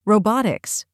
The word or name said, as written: robotics